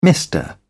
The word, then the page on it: mister: British English